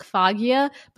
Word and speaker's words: Foggia: Okay, thank God I looked that up because it's spelled F-O-G-G-I-A, like Foggia, but it's Foggia.